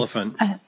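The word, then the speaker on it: Olyphant: Oliphant.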